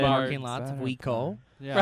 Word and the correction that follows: WinCo: The am I saying it right? Winco. That's Winco and all the candy they got there.